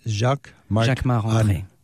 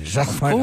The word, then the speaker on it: Jacquemart-André: Jacquemart-André. You say it like André. A Frenchman. Jacquemart-André. – Of course, because I'm French. So Jacquemart-André. – And say it so we can understand it. – Jacquemart-André.